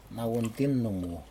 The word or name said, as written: nauintin numu